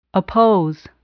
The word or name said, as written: oppose